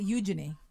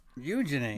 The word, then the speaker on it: Eugenie: Anyway, but they pronounce it Eugenie. – Eugenie.